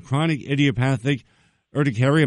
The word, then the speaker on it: chronic idiopathic urticaria: Two websites to learn more about chronic idiopathic urticaria.